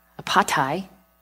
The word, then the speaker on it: apatai: The regular pronunciation, apatai, means deceiver.